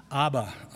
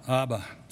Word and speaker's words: Abba: In Hebrew, how do you say Father? Abba, Abba. So there it is.